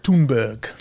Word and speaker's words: Thunberg: How do you say her last name? Thunberg? – Thunberg. – Oh, nice.